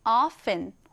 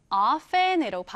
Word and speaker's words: often: 자, 발음할 때는요, 두 가지 방법이 있습니다. 첫 번째는 이 t를 살려서 often, 두 번째는 T 발음을 생략한 채로 often이라고 하는데요. 여러분은 often, often 어떤 것이 더 마음에 드시나요? 사실은 두 번째 T를 생략한 채로 발음하는 것이 훨씬 더 흔히 사용되긴 합니다. 그래서 오늘은 often으로 발음해 볼게요.